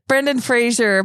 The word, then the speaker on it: Brendan Fraser: Didn't you see Brennan Frazier? – Where would I see Brennan Frazier? I don't even know what that is. – Brendan Fraser, apparently we've been saying his name wrong all these years, but he's in the new movie The Whale, and he wore what is called a fat suit.